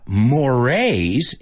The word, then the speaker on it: mores: Eso se escribe Moors, M-O-R-E-S, pero se pronuncia la E como A en esa.